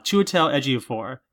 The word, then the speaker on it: Chiwetel Ejiofor: Eichner, yeah, as Timon, Chichitel Ejiofor, Chiwetel Ejiofor, yeah, that's how you say it, as Scar, John Oliver as Zazu, Florence Kasumba, Keegan-Michael Key and Eric Andre as Scar's hyenas.